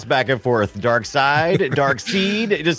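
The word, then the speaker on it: Darkseid: A French guy just told us it's pronounced Dark Seed.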